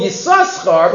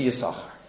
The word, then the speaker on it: Yisachar: He's called Yisachar because of the two schars. But the rest of the Torah, you don't pronounce it. You don't pronounce the sin because, you know it's Gennai.